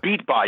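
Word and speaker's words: Bedenbaugh: Beatbaugh, yeah, that's what it is. It doesn't look like it would be that, but that's, I guess, how he pronounces it.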